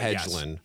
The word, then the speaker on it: Hedglen: which is Mary Alice Hedglen Ellicott. I hope I pronounced that correctly. – It's Hedglen, but Hedglen.